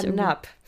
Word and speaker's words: NAP: Na, deshalb ist es ja NAP. – Achso, deswegen spricht man es einfach Deutsch aus.